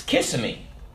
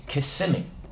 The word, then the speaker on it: Kissimmee: And someone said, the wife said, it's Kissimmee. You know, like kiss me. And the husband said, oh, no, it's not. It's Kissimmee.